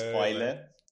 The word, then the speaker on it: Eule: Actually, it's Eule. Eule, but if you pronounce it like a small child or yeah, a small person, you might come to this.